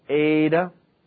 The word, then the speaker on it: eta: This is the eta. It's a long E, eta, pronounced like an A.